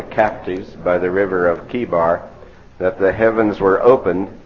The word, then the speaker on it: Chebar: We know that the word there, C-H-E-B-A-R, is pronounced Kebar.